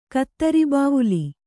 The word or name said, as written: ಕತ್ತರಿಬಾವುಲಿ